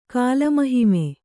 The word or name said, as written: ಕಾಲಮಹಿಮೆ